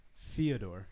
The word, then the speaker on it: Fyodor: Fyodor.